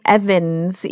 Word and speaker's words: Evans: It looks like Ewens. It's Stephanie Ewens. But it's pronounced Evans. It's weird. I know. – it is pronounced Evans.